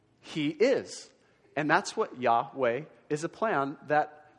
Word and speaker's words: Yahweh: Okay, so when you hear people say Yahweh or Jehovah, that's just two different ways of pronouncing the same name, which is this right here. And Yahweh is probably closer to the right thing.